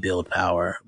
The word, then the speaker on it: BLDPWR: PWR, in reference to Build Power, that is the name of the company, how it's phonetically said, Build Power, but it's spelled BLDPWR.